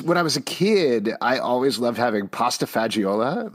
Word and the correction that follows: pasta fagioli: I think it's pronounced pasta fagioli.